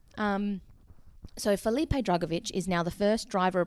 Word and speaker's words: Drugovich: Drugovich.